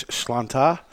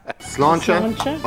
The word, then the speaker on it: Sláinte: Well, the Irish word for health is Sláinte, so Sláinte. – Sláinte, Ed Sullivan, the only Irishman here. – Yeah, we'll give you the correct pronunciation. – Sláinte, agus Sláinte.